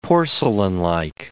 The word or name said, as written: porcelainlike